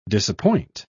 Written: disappoint

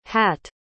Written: hatt

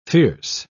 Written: fierce

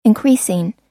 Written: increasing

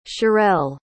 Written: shirelle

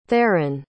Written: theoren

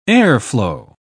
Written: airflow

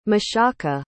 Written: mashaka

